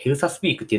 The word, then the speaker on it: ELSA Speak: エルサ、 ELSA にスピーク。って書いて、エルサスピークって言うんですけど、これ、発音の練習ができるんですよ。